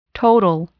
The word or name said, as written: total